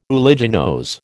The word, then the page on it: uliginose: American English